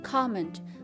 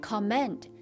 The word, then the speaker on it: comment: She says，第五个单词评论 comment，c o m 在一起发 com com，而且呢重音在最前面 comment，很多人都都读成了重音在后面变成 comment，那是错的。